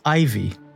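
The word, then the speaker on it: IV: One of the new series I have currently in development is spelled I-V, but pronounced Ivy.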